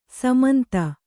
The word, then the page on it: ಸಮಂತ: samanta